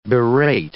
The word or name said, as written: berate